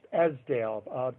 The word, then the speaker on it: Esdaile: And then from there, we go into the Esdaile state. Is that correct? – Well, actually, it's pronounced Esdaile.